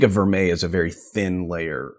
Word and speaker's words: vermeil: Or vermeil? Did I pronounce that correctly? – Yeah.